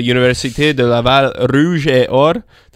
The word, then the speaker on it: Université de Laval Rouge et Or: This weekend against the Université de Laval Rouge et Or. Did I say that right? – You did. – In a good... – it was pretty good. It was a good French accent.